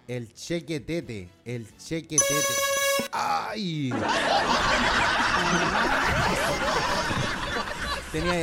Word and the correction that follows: Chiquetete: Chiquetete, esta cobardía, ahí está. Sí, se escribe tal cual como el chique, chiquetete.